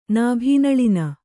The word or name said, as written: ನಾಭೀನಳಿನ